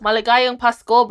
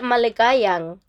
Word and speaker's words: Maligayang Pasko: Maligayang Pasko, bro.